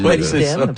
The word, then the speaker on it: molybdène: Moi, j'ai appris cette année du sagouin du molybdène. – Le molybdène. – Les minéraux. Déjà, comment bien le prononcer aussi. – Le molybdène, c'est ça. Bravo.